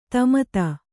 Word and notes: ತಮತ: kannada; tamata

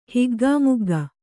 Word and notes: ಹಿಗ್ಗಾಮುಗ್ಗ: Kannada

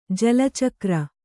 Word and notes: ಜಲಚಕ್ರ: jala cakra

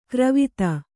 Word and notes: ಗೊರವಿತಿ: Kannada

